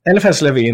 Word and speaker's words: Eliphas Levi: Well, Eliphas Levi, uh, to give him his French pronunciation, he got there first, and Eliphas Levi,